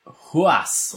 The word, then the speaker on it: Ruas: So it's spelled R-U-A-S, but it's pronounced Huas, Huas.